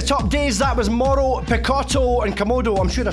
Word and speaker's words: Mauro: Mauro.